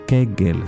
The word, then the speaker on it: Kegel: Kegel, se escribe K-E-G-E-L. Kegel. O Kegel, es como se pronuncia.